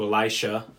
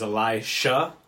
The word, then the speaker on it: Elisha: So, as I understand it, the proper pronunciation is Elisha, but a lot of people tend to avoid it because it's easy to confuse with Elijah. So, yeah, Elisha is correct, but you'll often hear variants and there's a reason for that.